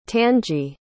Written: tangie

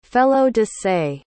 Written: felo-de-se

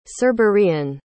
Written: cerberean

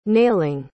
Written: nailing

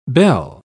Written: belle

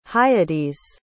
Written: Hyades

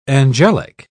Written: angelic

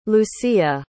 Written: lucía

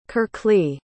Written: kirkly

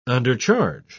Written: undercharge

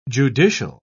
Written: judicial

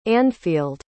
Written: anfeald

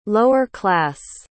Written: lower-class

